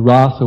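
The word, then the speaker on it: rāsa: And the rasa with a long A is the dance that Krishna does for the gopis in the enacting of his conjugal rasa with a short A with them. So it makes a big difference, actually, just a long A or short A.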